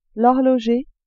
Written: horloger

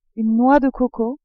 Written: noix de coco

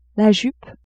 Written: jupe

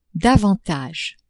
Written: davantage

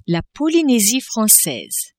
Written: Polynesie francaise

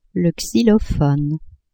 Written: xylophone

